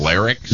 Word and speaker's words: larynx: It's larynx. – Yes. Larynx? – Yeah. No, larynx. Not, larynx. – Larynx? – Yeah, not larynx.